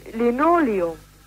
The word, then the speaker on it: Linoleum: An für sich haben wir mit, also ich für meinen Teil sage Linoleum. – Ja, und das ist auch amtlich richtig.